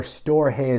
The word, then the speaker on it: storge: And then there is storge, or storge as some people might pronounce it.